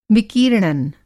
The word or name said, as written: विकीर्णन